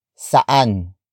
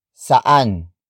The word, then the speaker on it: saan: saan, ang, bahay, ni, Alice. Saan, ang, bahay, ni, Alice.